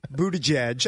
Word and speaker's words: Buttigieg: So you've taken Buttigieg, right. I believe is how you pronounce his name.